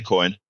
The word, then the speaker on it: Cohen: And come on in, Cohen.